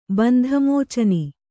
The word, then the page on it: ಬಂಧಮೋಚನ: Kannada; bandha mōcana